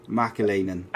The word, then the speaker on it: McAleenan: Mcaleenan.